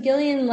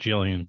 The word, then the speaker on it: Gillian: Um, but yeah, I mean, also Gillian, like she. – I'm sorry, she, I said Gillian. Gillian. – I think she probably pronounces it both ways,